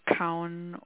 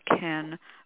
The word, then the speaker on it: Kaun: So that last rune I have here is called Kaun or Kaunen. Different people pronounce it. I was taught to call it Ken.